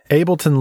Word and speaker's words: Ableton: I'm glad to hear you say it out loud, because I've always said Ableton Live, but you never know, like, is it like Abe Lenton? Like, Is that their name? How do I pronounce this? So, Ableton Live.